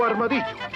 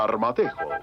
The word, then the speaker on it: armadillo: Por ejemplo, esta extraña forma de cesto, este es el Deicipoidae, o armadillo. Algunas veces se pronuncia armadejo, pero cariñosamente todos le dicen tatú.